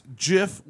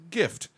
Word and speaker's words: GIF: Pronounce the word as GIF with a soft G. As in Jim. Yes. Steve Wilhite says that the attended pronunciation deliberately echoes the American peanut butter brand Jif, and CompuServe employees would often say choosy developers choose Jif, spoofing the brand's television commercials.